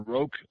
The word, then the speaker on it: Rokke: You know, if anybody's heard of Doug Rock, there's different pronunciations, Rock or Roke, R-O-K-K-E.